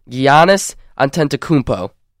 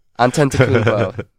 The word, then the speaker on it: Giannis Antetokounmpo: Jabari Parker, Brandon Knight, Larry Sanders, a good young center, and my favorite of all of them is the Greek freak, and I'm going to try and pronounce this as well as I can, Giannis Antetokounmpo. – I think you did a good enough job. – Antetokounmpo.